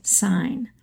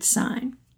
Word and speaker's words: Sayen: The photographer's name turns out to be properly pronounced Gertrude Sayn. The last name is spelled S-A-Y-E-N with an umlaut over the Y, and Sarah and I, during the episode, mistakenly were pronouncing it as Sayn. It's really Gertrude Sayn.